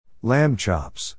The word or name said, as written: lamb chops